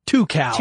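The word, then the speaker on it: Tucows: Two cows.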